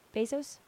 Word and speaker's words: Bezos: Jeff Bezos? – Yeah, that's good for you.